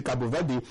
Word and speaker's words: Cabo Verde: Cabo Verde, like they pronounce it themselves, we call it Cape Verde, but they call it Cabo Verde.